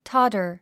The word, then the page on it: totter: tɑ’tə r; タァダァ